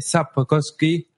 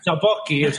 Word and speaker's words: Sapkowski: Apoyo de el señor Andre Sapkowski. – No sé si lo he dicho bien. – Ah, no, no escupido, no lo has dicho bien. Sapkowski.